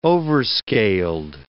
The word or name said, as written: overscaled